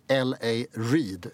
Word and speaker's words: L.A. Reid: och en skivbolagsmagnat som heter L.A. Reid, uttalas det, va? – Ja.